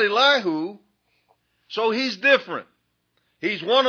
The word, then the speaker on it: Elihu: Did you notice that Elihu, sometimes I call him Elihu, I'm trying to say Elihu, that's the correct pronunciation.